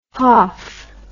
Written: hauf

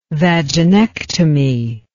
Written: vaginectomy

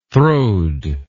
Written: throwed

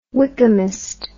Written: Wykehamist

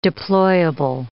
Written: deployable

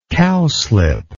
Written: cowslipped

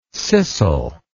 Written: scissel

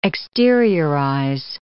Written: exteriorize

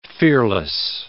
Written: fearless

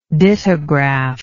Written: dittogram

